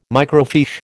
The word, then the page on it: microfiche: American English